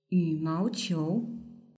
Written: yumaoqiu

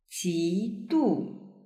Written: jidu

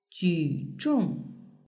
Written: juzhong

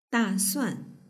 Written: dasuan

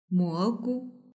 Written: mogu

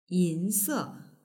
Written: yinse

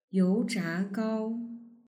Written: youzhagao

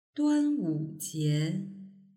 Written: duanwujie

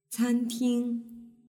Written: canting

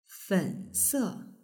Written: fense

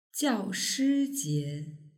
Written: jiaoshijie

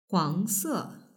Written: huangse

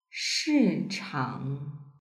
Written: shichang

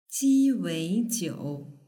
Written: jiweijiu

